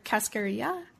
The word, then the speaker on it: cascarilla: Quina or cascarilla? Is that right? – That is correct also, Yay.